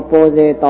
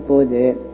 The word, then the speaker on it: 般若: 咱今日要讲这第六道般若道，这般若道有三种：第一是实相般若，第二是观照般若，第三是闻智般若。这个般若呢，那照音大概是念般若，但是这是因为印度话对印度音翻过，所以应该读作般若。